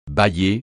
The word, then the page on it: bâiller: francais